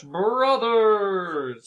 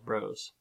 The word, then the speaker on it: Bros.: Ah, so, the point is, yes, we understand that, that bros with a period after it, as it's done in the title, is technically an abbreviation for brothers. It's still pronounced bros.